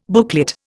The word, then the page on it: booklet: Indian